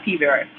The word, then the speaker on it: P. vera: I have been to several hematologists over the years concerning my P. vera. Did I say that right? – Yeah, P. vera.